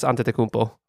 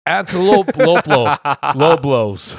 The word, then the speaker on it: Antetokounmpo: Nomar Garcia Para was the last name that was more difficult to spell prior to Giannis Antetokounmpo. – Oh, shit. You were probably the first person to actually pronounce it properly. I was so shocked. I was like, Giannis Antelope Loblos.